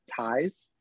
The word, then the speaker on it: Tyis: One is at Tyes, T-Y-I-S underscore E-Y-E-S.